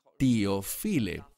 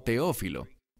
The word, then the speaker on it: Teófile: Y noten que dice aquí que está escribiéndole a, literalmente dice en griego, Teófile. Normalmente pronunciamos esto como Teófilo. Este término, que en griego es Teófile, simplemente significa uno que ama a Dios.